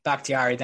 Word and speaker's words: Bakhtiari: David Bakhtiari, I think it's Bakhtiari, thank you. I knew I said that wrong as soon as it came out, with a knee.